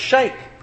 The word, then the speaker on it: Sheikh: We've only in recent years learned how to pronounce Sheikh, haven't we? Remember that so many songs and movies we used to hear the term Sheikh used. And then we find out after 9/11 it was Sheikh.